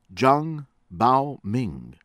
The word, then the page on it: AI WEIWEI: EYE WAY WAY